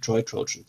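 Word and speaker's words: Troy Trojans: für die Troy Trojan, so ist es richtig ausgesprochen.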